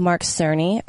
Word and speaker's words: Cerny: So the interview was with Mark Cerny.